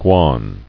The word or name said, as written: Guan